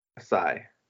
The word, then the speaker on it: Esai: It's E-S-A-I. How would you pronounce that? – Esai.